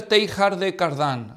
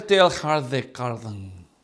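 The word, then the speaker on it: Teilhard de Chardin: Luego tenemos a uno de los más notables e influyentes teólogos católicos modernistas, que se llama Pierre Teilhard de Cardan. Así se pronunciaría en francés. Bueno, con un acentote más hispano, ¿verdad? O Pierre Teilhard de Cardan.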